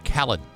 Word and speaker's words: Khaled: Is it Khalid or Khalid? I think it's Khalid. – DJ Khaled.